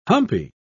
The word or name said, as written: humpy